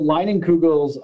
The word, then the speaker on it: Leinenkugel's: How did the partnership with, is it Leinenkugel's? Is that how you say it? – Yeah. So Leinenkugel's,